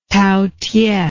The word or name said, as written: taotieh